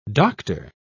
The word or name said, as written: dr